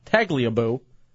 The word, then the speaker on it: Tagliabue: How do you say his name? – Tagliabue.